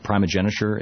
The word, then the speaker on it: primogeniture: And in fact, his first legislation he ever presented when he became in the House of Burgesses was to stop the laws of primogeniture. Is that the correct pronunciation? – Yeah.